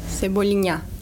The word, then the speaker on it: Cebolinha: Cebolino? – Cebolinha. – Cebolinha. – Oh, that's good.